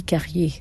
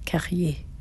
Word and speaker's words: Carrier: So my real name, with the real pronunciation, Danielle Julie Carrier, the R, Carrier.